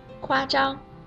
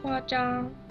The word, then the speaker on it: 夸张: 夸张的“ 张” 声母是 zh，卷舌发音；而夸奖的“ 奖” 是激昂奖。激在发音的时候，舌头要顶住口腔的上面，不送气发音。所以你听啊，我再念一遍：夸张，夸奖。 – 啊，我也说一遍。夸张，夸奖。